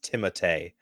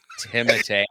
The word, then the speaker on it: Timothée: So he tells people to call him Timothy Chalamet, and but the real pronunciation is Timotei. – Timotei.